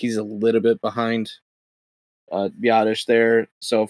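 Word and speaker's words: Biadasz: How do you say the Wisconsin guy's last name? I don't want to say it wrong. – Biotish. – Okay, Biotish. That's what I thought.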